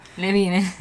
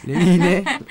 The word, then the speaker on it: Levine: El estilo grunge de la voz del vocalista Adam Levine, o Levine, no sé cómo se pronuncia. – Levine. – Levine. Acá sería Levine.